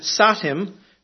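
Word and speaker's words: satem: and then the Avestan satim, which starts with a sibilant.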